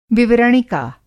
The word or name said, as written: विवरणिका